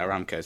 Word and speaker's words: Aramco: So thank you for that, Aramco. Yeah, what was the other one that stuck out for me? – He's adding an extra A into the word Aramco there. – Oh, yeah, Aramco, sorry.